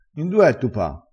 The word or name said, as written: terre